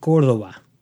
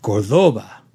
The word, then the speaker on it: Córdoba: Or, as Ricardo Montalban said in the Chrysler commercials, Córdoba. But unfortunately, as it turns out, he was putting the emphasis on the wrong syllable, as the accent is over the O.